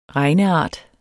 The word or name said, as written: regneart